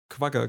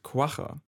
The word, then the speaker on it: Quagga: Noch eins vorweg, wir haben in der Straßenumfrage das Tier Quagga genannt. Das ist die eingedeutschte Aussprache des Namens und auch der, unter dem die meisten Menschen es kennen. Die eigentliche Aussprache lautet Quagga.